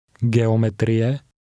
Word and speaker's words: geometrie: Geometrie. Geometrie. – Notice that the Czech letter G is always pronounced with a hard sound, as in the word guitar.